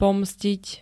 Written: pomstiť

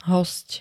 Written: hosť

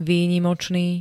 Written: výnimočný

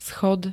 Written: schod

